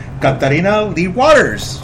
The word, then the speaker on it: Katarina Lee Waters: I'm talking about the one and the only, the lovely, the beautiful, Katarina Lee Waters.